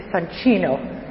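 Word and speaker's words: Soncino: I was always pronouncing it Sansino, and then I gave a lecture a few weeks ago in Tel Aviv, and a young Italian man walked over to me and said, "Very nice lecture, but you kept saying Sansino. It is Sancino." So I'll continue saying Sansino, but just with the awareness that the correct pronunciation is Sancino.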